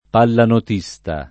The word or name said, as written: pallanotista